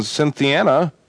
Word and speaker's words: Cynthiana: At 1400, WCYN in Cynthiana, I hope that's pronounced right. Is that right, Bob? Am I getting the name right there? – That's right.